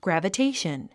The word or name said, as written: gravitation